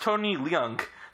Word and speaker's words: Tony Leung: It turns out it's pronounced Tony Leung. So I did mispronounce it.